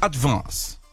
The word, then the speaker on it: advance: É um A puxando pro E, ó. Que vê a diferença, tá. Ó. Advance. Aí você falou advance. Mas, ó, é um E puxando. Ó, é advance.